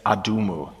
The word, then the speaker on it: Adumu: and they encamped against Adumu, that's how you pronounce Edom in the Babylonian language, and he defeated the army.